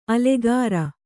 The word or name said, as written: ಅಲ್ಲೀಕೇರಿ